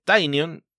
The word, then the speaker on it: Tynion: Y en el caso de Tynion, la forma más fácil de saber cómo lo pronuncias es viendo el nombre que le puso a la entidad, a la compañía bajo la que registra sus cómics propios, ¿no? Que es Tiny Onion, jugando ahí con cebolla diminuta y la forma de pronunciar su apellido.